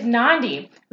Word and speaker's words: Nandi: My name is Nandi. Rhymes with Gandhi, and I am the host of this podcast, this party, Believe Like a Boss.